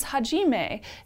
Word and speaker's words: Hajime: The second vigilante is Hajime. It's spelled kind of like Hajime Mashite, and Hajime, I believe, means beginning in Japanese, so I'm pronouncing it that way.